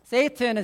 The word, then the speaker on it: Satan: Satan is actually pronounced Satan, which would be the Hebrew version.